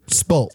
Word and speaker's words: Spult: Am I still pronouncing that correctly? Spolt. – Absolutely. Absolutely perfect.